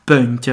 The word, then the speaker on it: Pönt: E qui a Resi effettivamente ci sono moltissimi luoghi che portano il nome di sasso di pietra che in resiano si chiama Pönt. Ho detto giusto? – Sì.